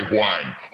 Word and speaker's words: Huang: This guy, Huang. Huang. It's a right. Silent H.